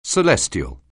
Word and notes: celestial: anglais

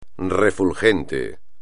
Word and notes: refulgente: espagnol

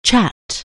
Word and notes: chat: anglais

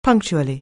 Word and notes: punctually: anglais

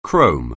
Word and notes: chrome: anglais

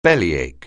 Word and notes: bellyache: anglais

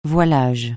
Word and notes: voilage: francais

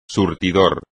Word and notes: surtidor: espagnol

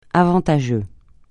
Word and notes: avantageux: francais

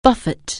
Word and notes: buffer: anglais

